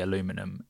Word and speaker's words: aluminium: I was like, oh no, it is actually aluminium, and we changed it for no reason.